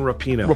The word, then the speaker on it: Rapinoe: How do you say her name again? Rapinoe. I was saying her name wrong. – Megan Rapinoe.